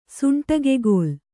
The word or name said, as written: ಸುಂಟಿಗೆಗೋಲು